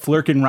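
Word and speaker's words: Flerken: Let's talk about that Flurkin rhymes with Merkin.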